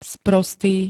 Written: sprostý

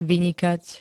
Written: vynikať

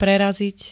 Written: prerazit

